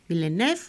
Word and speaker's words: Villeneuve: Una película dirigida por Denis Villeneuve, Villeneuve, ¿lo he dicho bien? ¿Tony Herrero? – Sí, sí, bien.